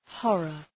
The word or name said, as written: horror